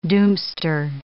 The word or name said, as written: doomster